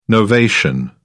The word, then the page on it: novation: British English